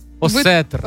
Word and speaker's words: осетр: А козероги у нас осетер. – Осетр. – Осетр.